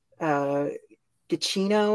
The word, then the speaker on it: Giacchino: It's Michael Giacchino or whatever.